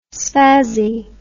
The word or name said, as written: spazzy